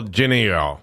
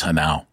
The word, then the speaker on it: Genao: Well, the American version would be Angel Geneo. – Angel Geneo. – Angel Geneo, yeah. – Instead, it's Angel Hanau. – Okay. – That's the way that his name is pronounced, is Hanau, even though it's Genao.